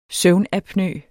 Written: søvnapnø

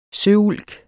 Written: søulk

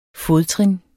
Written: fodtrin

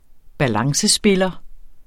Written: balancespiller

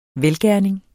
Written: velgerning

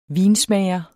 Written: vinsmager

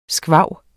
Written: squaw

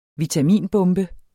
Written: vitaminbombe

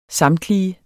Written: samtlige

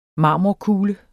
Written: marmorkugle

